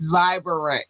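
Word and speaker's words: Vivrant: It's Vibrant because you have to sound out the syllables.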